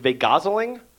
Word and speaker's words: vajazzling: Although Jennifer Love Hewitt actually pronounces it, it's vagazzling, so that's how I have to pronounce it because there's a G instead of a J.